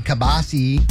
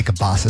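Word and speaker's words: kielbasa: It looks like we're going to have a decent weekend for the Kabasi Festival in Plymouth. I don't want to say Kabasa so bad. I know it's not the right way to say it.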